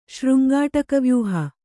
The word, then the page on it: ಶೃಂಗಾಟಕವ್ಯೂಹ: Kannada